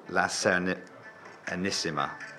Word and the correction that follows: La Serenissima: La Serenissima, which I should be able to pronounce.